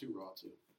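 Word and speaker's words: Räty: His last name is spelled R-A-T-Y. And it's pronounced, his name is called Ratu.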